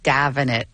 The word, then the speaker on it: Damhnait: It's spelled D-A-M-H-N-A-I-T, which is Irish Gaelic. How do you get Davenant out of that?